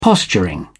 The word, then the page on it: posturing: British English